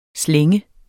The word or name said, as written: slænge